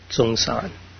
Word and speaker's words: Sengcan: And his name is spelled S-E-N-G-C-A-N, and it's pronounced Songsan.